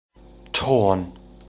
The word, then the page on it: Thorn: German